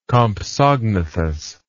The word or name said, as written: compsognathus